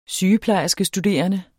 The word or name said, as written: sygeplejerskestuderende